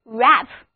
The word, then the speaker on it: wrap: 好了，我们再来看一个跟 pack 意思差不多的单词 wrap. W-R-A-P. 字母组合 W-R 发 R 的音。 A 字母是 A 梅花音。这个单词就读作 wrap, 意思是包、捆、包好。